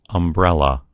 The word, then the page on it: umbrella: American English